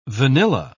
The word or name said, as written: vanilla